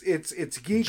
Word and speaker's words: GiGO: It's Gigo. – G-I-G-O. – Yeah, it's Gigo because it's an acronym. – Okay. – For getting into the gaming oasis. So, Gigo.